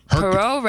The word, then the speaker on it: Poirot: Poirot, right? – Not Hercules, though.